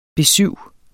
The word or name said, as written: besyv